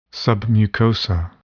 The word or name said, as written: submucosa